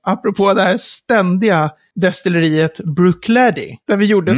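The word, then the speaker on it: Bruichladdich: Så hon vet banne mig hur man ska uttala det här destilleriet. Ah, hon säger, säker. och det här är ingen överdrift, hon säger Brookleddy.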